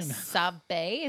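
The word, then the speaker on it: Sabé: Sabe, is that how you say her name? – Yeah, played by Keira Knightley.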